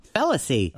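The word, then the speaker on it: Félicie: It's spelled F-E-L-I-C-I-E. – Yeah. – Oh. – On the website here. – Felicy. – Yeah, whatever.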